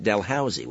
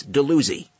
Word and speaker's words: Dalhousie: It's Dalhousie, but you can tell if someone's from out of town when you're in Brantford because they pronounce it Dalhousie, which is the way it's spelled and it's the way it should be pronounced. But a native Brantfordian knows that it's not Dalhousie, it's Dalhousie.